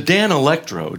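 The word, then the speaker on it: Danelectro: The Dan Electro, did I say that right? – You did.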